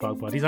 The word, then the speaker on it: Bog Bodies: I tell you what, I thought it was called Boge Bodies. – Boge Bodies. That's one of classic mispronunciation bits. – It is. It's pronounced Bog Bodies.